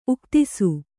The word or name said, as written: ಉಕ್ತಿಸು